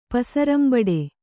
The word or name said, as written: ಪಸರಂಬಡೆ